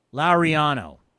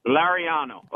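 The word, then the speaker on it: Laureano: And can you pronounce his name? Because as an old hockey guy, I'm tired of calling him Lariano. – Think of Jed Lowry. Lariano. – Lariano. Okay, Lariano. Good. I will not mispronounce the name again.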